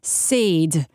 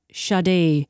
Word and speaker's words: Sade: I pronounced Sade instead of Sade earlier on, and some people were not happy, but it has got us now discussing mispronounced names.